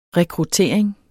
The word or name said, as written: rekruttering